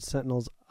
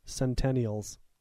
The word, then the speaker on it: Sentinels: And so it's just a long-winded way of me saying that as I read the early X-Men that featured Sentinels, I always pronounced it Centennials. And I thought that was kind of a cool name.